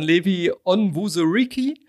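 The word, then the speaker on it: Levi Onwuzurike: Und äh, da wollte ich zum Thema Interview, das passt aber, das fand ich aber auch ein geiles Interview von Levi Onwuzuriki Mhm.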